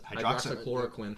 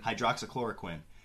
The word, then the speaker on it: Hydroxychloroquine: Hydroxychloroquine. – Hydroxychloroquine.